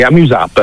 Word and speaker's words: Amuse App: È Amuse App, in modo che si possa leggere sia all'italiana che all'inglese, cioè come funziona? – Sì, Amuse App, okay. Noi lo pronunciamo così.